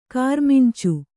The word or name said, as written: ಕಾರಮಿಂಚು